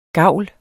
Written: gavl

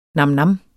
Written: NAMNAMS

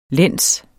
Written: læns

